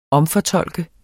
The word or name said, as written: omfortolke